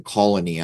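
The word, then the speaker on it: Cologny: The WEF headquarters is in Colony. I think I'm saying that right. C-O-L-O-G-N-Y. I think the G is silent, but I didn't look it up.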